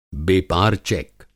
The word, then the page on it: बेपार चेक: Hindi